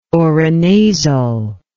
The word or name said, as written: orinasal